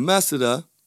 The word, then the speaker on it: masseter: And that muscle is called the masseter. Or depending on where you come from in the world, the masseter. Yes, spelled M-A-S-S-E-T-E-R. But I pronounce it the masseter.